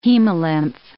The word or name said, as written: hemolymph